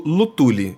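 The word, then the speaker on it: Luthuly: na sexta-feira do Lutuli. Eu acho que, é fala, acho que se fala assim, Lutuli, Lutuli, já, já vi pessoas pronunciando de diversa forma. De qualquer forma, é L-U-T-H-U-L-Y.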